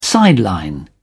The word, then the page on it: sideline: British English